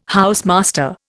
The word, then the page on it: housemaster: Indian